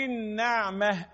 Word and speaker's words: النعمة: النعمة أنا لا النون مفتوح وليست نون مكسورة النعمة قولي النعمة أنا أوعى تكسر النون أن هتغير المعنى